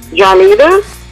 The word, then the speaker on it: Johnita: Johnita. – Come on, spell it for me, baby. – J-O-H-N-I-T-A.